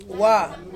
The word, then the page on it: wa: Ansus